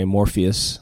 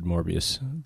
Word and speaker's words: Morpheus: And as soon as they start orbiting, this guy Morpheus, I actually said it wrong, I said Morbius.